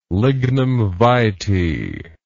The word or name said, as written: lignum vitae